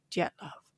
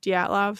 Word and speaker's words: Dyatlov: And I will probably mix between trying to say it like the actual Russian way, which is like Dyatlov or Dyatlov. So I'm sorry.